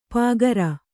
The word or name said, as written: ಪೂಗಾರ